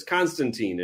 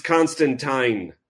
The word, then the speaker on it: Constantine: I think it's even written out in the dialogue where somebody says Constantine, and it's spelled T-E-E-N. Yeah. And John says, it's Constantine, like constant wine or something like that.